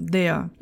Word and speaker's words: der: Pega aí como exemplo o artigo masculino no nominativo, der. Eu não pronuncio o R, você percebe? Eu não falo então der ou der. Eu falo der, como se fosse um A com uma boca menos aberta.